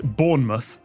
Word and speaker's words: Bournemouth: I love that you pronounced it like vermouth. It is unfortunately just Bournemouth because British English reduces every single vowel. – M-U-T-H sounds like mooth.